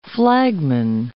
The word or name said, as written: flagman